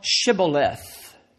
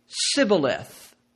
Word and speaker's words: Shibboleth: They were like them, and they couldn't pronounce their SH sound. They couldn't form it with their mouth. It wasn't part of their dialect. And so they would always say Sibboleth instead of Shibboleth.